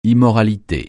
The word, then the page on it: immoralité: francais